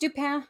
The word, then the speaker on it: Dupin: I think it's Dupin.